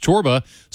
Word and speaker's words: Csorba: That is by Thomas Chorba. Now, if you've yet to discover him, you're going to need help spelling that last name. It's pronounced Chorba, spelled C-S-O-R-B-A.